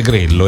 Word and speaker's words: Grello: Veramente molto molto bella, perché parliamo della nostra storia, parliamo della storia del borgo di Grello, si scrive Grello e si legge Grello.